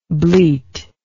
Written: bleatingly

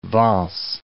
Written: Vence

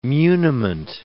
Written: muniment

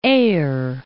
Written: Aire

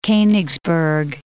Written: Konigsberg

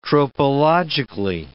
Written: tropologically

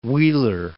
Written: Wheeler Peak

